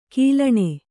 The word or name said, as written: ಘಲ್ಲಣಿ